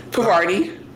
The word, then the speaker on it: Parvati: Parvati. Parvati. – And I'm glad she said it like that because I'm like, she needed to break it down for me because I was saying it wrong this whole time.